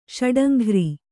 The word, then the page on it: ಷಡಂಘ್ರಿ: Kannada